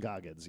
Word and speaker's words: Goggins: Walton Goggins, yes.